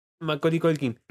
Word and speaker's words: Macaulay Culkin: Y actúa el hermano de Macaulay Culkin, o como se pronuncia, no me salió bien. Sí, Macaulay, Mac- Macaulay Culkin.